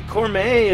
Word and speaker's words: Cormae: And anyway, she took off for Austin, Texas, and started a really good band called Cormae, I think it's pronounced. – Mm-hmm. – C-O-R-M-A-E, Cormae.